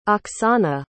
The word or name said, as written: oksana